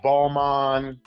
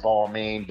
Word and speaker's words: Balmain: We got Holcomb, we got Antonio and Salvi, we have Balmain. People pronounce it Balmain, but it's really pronounced Balmain.